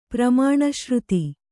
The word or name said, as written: ಪ್ರಮಾಣಶ್ರುತಿ